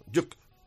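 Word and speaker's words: Jug: con un esloveno como árbitro, Matej Juk, así hay que pronunciarlo, Juk, vaya tela, y 4.000 béticos que estará mañana animando a su equipo.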